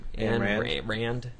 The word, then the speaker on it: Ayn Rand: Ayn Rand.